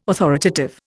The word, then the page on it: authoritative: Indian